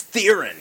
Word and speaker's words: Theron: She loves correcting people on the pronunciation of her name. – Yeah. – Oh, it's Theron.